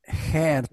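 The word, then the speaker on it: Geert: E, ama Avrupa Birliği düşmanları e, Bolverinden değil ama bundan böyle Hollandalı Donald Trump diye anılan Gert Wilders. Gert diye okunuyor değil mi? – Hert Wilders. – Hmm. Hert.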